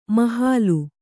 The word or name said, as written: ಮೊಹಲ್ಲಾ